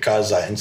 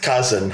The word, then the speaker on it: Kaizen: In my business school, the Americans might have mispronounced it Kaizen, so Kaizen, huh? – Kaizen, which means like continuous improvement, yeah.